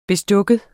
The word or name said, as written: bestik